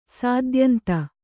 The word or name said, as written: ಸಾದ್ಯಂತ